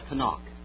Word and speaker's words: Tanakh: The Tanakh.